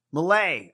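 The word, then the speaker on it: Milei: I tell you what, though, that Javier, how do you say his last name? Milieu? Malay. Oh, well, that was way off.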